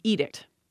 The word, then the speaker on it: edict: Let's actually start with the more straightforward one in the pairing we started with, which is edict, in some way pronounced how you would expect it to be. That word was borrowed into English from Latin. Edictum comes into English. Then we keep that pronunciation. There we go. Now, indict. I-N-D-I-C-T.